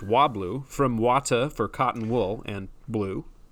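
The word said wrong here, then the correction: Watte: Oh, excuse me. Vate. That's right. I always mess up my German W's.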